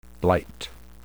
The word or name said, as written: blight